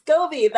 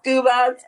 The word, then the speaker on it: SCOBY: SCOBY, that's it. That's what it's called. – Yeah. – Scuba, SCOBY, I know. – SCOBY. – I always say it wrong too.